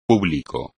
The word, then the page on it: público: espagnol